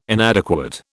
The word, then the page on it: inadequate: British English